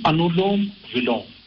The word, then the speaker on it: Anulom Vilom: It's called Anulom Vilom. A-N-U-L-O-M. Anulom V-I-L-O-M. Anulom Vilom.